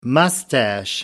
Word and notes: moustache: American English

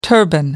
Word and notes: turban: American English

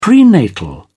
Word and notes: prenatal: British English

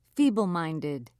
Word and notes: feebleminded: British English

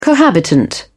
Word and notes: cohabitant: British English

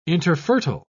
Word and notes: interfertile: British English

